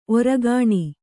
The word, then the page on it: ಓರೆಗಣ್ಣು: kannada